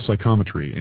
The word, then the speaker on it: psychometry: I think, is it psychosymmetry? Is that what when you touch something, is that what it's called? – Yeah, it's called psychometry. And psychometry, I always say it wrong.